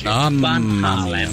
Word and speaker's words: Van Halen: Van Halen non è cognome di origine anglofona, per cui va pronunziato come si legge. Van Halen, ma lui è americano. – Van Halen, come fanno gli americani.